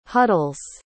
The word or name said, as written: huddles